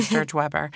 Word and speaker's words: Sturge-Weber: So, Jameson has a rare disease called Sturger Weber, right? – Yeah. – Sturge Weber. I'm saying that incorrectly. – Sturge Weber.